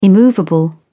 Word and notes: immovable: British English

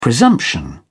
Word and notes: presumption: British English

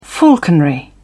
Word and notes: falconry: British English

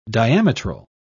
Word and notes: diametral: British English

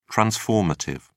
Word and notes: transformative: British English

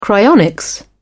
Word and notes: cryonics: British English

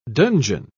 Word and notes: donjon: British English

